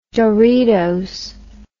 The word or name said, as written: doritos